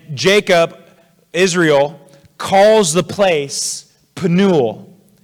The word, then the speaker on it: Penuel: Now, Phanuel is the Greek pronunciation of the Hebrew name Penuel.